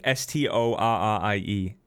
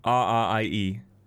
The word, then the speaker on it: Storrie: Storrie. – Story Lake. Wait. Story? How do you spell it again? – Storrie. – I-E. Story Lake, New Mexico.